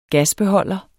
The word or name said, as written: gasbeholder